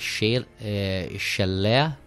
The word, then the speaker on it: shillelagh: Am I pronouncing this right? A shillelagh? Shillelagh. – A shillelagh is a thick stick. Normally it's a blackthorn or oak. It's a fighting stick. So it's an Irish word, a shillelagh.